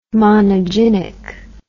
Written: monogynic

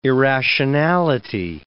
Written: irrationality

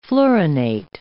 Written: fluorinate

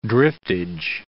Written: driftage

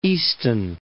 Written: Easton

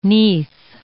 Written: neath

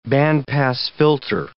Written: band-pass filter

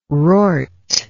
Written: rort

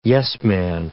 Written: yes-man

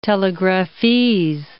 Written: telegraphese

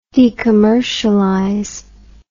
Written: decommercialize

